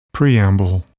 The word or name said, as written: preamble